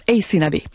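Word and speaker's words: Aysanabee: Alors, je vous appelle, son nom, c'est A-Y-S-A-N-A-B-E. Ça se prononce Ace Nabé.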